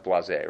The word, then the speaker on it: blasé: It's pronounced blasé, right? B-L-A-S-E. Yeah.